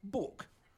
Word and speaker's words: book: Almost no one any longer said book to rhyme with took or look or even fuck, as in fuck you, you bastard. Which was the way it was pronounced in flat-voweled, lawless Lancashire, just a few miles to the north of the sedate, sleepy peat bogs of Cheshire, where I grew up.